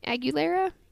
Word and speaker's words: Aguilera: So, this is an article by Jasmine Aguilera. I think that's how you say her name. Aguilera? – Nailed it.